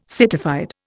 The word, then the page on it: citified: Indian